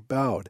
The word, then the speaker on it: Boud: The topic of energy drinks was brought up in the December 2008 issue of Ensign magazine in an article written by Thomas J. Bowd. It's spelled B-O-U-D.